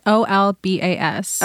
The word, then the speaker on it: Olbas: Yeah, Olbus. It's Olbas. – Okay. – I might not even be pronouncing that right. – Olbas.